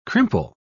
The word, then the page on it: crimple: British English